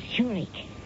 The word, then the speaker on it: Zurich: That's pronounced Zurich, as in the Swiss city.